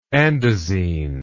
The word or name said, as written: andesine